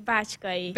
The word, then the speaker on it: Bácskay: Bácskay.